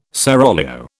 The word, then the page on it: seraglio: British English